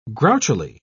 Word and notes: grouchily: British English

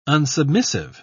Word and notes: unsubmissive: British English

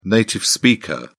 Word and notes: native speaker: British English

